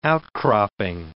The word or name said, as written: outcropping